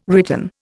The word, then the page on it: written: Indian